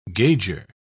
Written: gauger

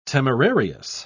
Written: temerarious

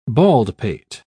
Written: baldpate

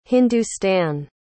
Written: hindustan

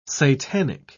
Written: satanic

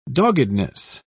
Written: doggedness

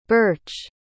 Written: birche

